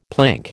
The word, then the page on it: plank: American English